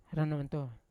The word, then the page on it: rananto: Yawa